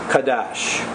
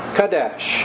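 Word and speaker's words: qadash: The Hebrew word that is translated here in verse 3, sanctified, is kadash. Kadesh, and I'll spell it for you. It's Q-A-D-A-S-H. Q-A-D-A-S-H.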